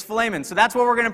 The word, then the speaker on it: Philemon: And I found out that it's actually pronounced Philemon according to the Greek. The E is a shorter E. It is not a long E.